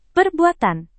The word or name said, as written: perbuatan